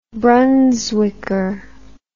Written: brunswicker

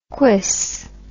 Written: quis

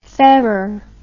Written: Ferrar

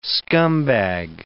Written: scumbag

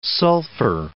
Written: sulphur

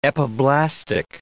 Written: epiblastic

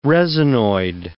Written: resinoid

